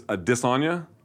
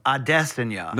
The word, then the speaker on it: Adesanya: I'm told it's Adesanya. – No, Adesanya. – No, I'm told it's Adesanya.